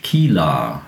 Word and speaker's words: Kilar: Aber äh, die Musik ist von, ich kann nicht aussprechen, ähm, Wojcic, Wojcic Kila heißt der Mann. Also Nachname mhm. ist K-I-L-A-R.